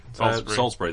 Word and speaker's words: Salisbury: Salisbury, that's how you say it.